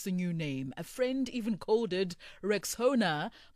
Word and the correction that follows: Rexona: but guys, it's Rexona, like Rex and Ona.